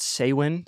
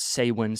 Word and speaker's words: Samhain: So started out calling, it's called Samhain. So it's spelled S-A-M-H-A-I-N. So yeah, I had to Google that. I did not know that before, but it's called Samhain. So S-A-H-W-N. So like Samhain or Samhain, kind of depends on your preference, I guess.